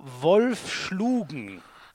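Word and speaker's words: Wolfschlugen: Die hat begonnen beim, ich liebe das, wenn man immer diese, äh, diese Dorfvereine, also ich nehme an, das ist einer, der TSV Wolf Schlugen. Habe ich das richtig ausgesprochen? Ja.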